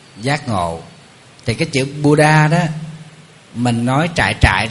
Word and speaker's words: Buddha: Nguyên văn cái chữ đó đọc là Buddha, chúng ta phiên âm là Phật Đà, người Trung Hoa phiên âm là Phật Đà.